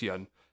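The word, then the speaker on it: Pyun: I think it's Yun. It's P-Y-U-N. So I think the P is silent.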